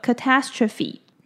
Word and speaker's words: catastrophe: 那比较需要注意的地方是呢，这个字的字尾三个字 phe 是发 phe 的音，所以念作 catastrophe。